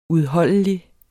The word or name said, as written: udholdelig